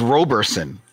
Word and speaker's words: Roberson: Chris, and I believe that's Roberson. – Is it Roberson? – Okay.